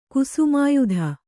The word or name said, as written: ಕುಸುಮಾಯುಧ